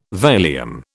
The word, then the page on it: Valium: British English